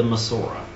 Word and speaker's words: Masorah: And then they also have the Masorah. And I'm not even certain I'm pronouncing that word right, so I don't know, Pastor Rick, if you know for sure. – I think that sounds right.